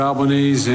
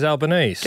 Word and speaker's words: Albanese: How do you say Japanese? – Japanese. – Spelt the same way as Albanese. – Japanese. – Albanese. – Do you know what he did? – He Italianed it up. Albanese.